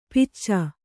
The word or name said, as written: ಪಿಚ್ಚ